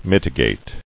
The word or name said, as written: Mitigative